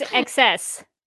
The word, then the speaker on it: XS: XS? Is that how you pronounce? Or is she like X? – Sorry, I took you out of the screen. I was trying to search. – Oh, no. – It's XS. It's XS.